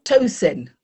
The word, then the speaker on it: Tosin: Tosin. – Tosin. Okay, just like it looks.